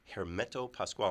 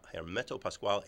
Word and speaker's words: Hermeto Pascoal: just search on the name Hermeto Pascoal. I'm going to spell it again uh, Hermeto, H E R M E T O. And Pascoal is P A S C O A L. Hermeto Pascoal e Grupo.